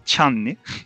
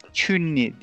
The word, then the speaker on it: Chun-Li: Chun-Li Bizim deyimimizde gençlik zamanımızda bildiğimiz haliyle Çanli. Biz ona Çanli diyorduk Çanli. – öyle okunur diye herhalde. – Çünli diye okunuyor muhtemelen. Şimdi İremcim sana nereden başlayayım?